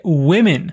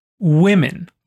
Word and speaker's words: women: O plural mulheres é women, com um E no lugar do A. A pronúncia também muda um pouco women, plural, women.